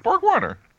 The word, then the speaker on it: BorgWarner: How would you say Borg Warner? Borg Warner. – Yeah, that's how I would say it.